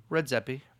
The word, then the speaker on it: Redzepi: Redzepi.